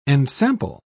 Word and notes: ensample: British English

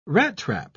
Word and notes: rattrap: British English

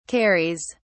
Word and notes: carries: British English

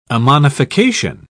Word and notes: ammonification: British English

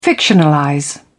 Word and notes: fictionalize: British English

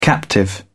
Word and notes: captive: British English

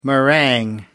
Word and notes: meringue: American English

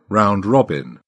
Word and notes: round robin: British English

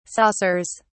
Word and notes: saucers: British English

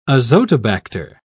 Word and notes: azotobacter: British English

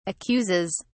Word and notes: accuses: British English

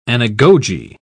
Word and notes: anagoge: British English

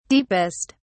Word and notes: deepest: British English

